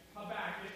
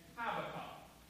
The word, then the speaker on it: Habakkuk: by making mention that there are indeed a couple of different ways to pronounce the title and the author of this book. For some people say Habakkuk, like I just did, and others say Habakkuk.